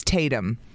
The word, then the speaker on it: Taytum: We got a text that said, my son's name is Phoenix, F-E-N-I-X, pronounced Phoenix like the city. – Okay. – My daughter is Tatum. T-A-Y-T-U-M.